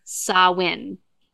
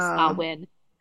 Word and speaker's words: Samhain: Now, he's Celtic and the Celtic community kind of was like, please, his name is Samhain. That's how you pronounce the name is Samhain.